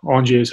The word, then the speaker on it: Angers: It's Angers is the spelling, but Angers is how you say it.